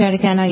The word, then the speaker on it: Raducanu: Serena Williams has lost to Emma Raducanu. Is that how you pronounce it? – Mm-hmm. Raducanu, yep.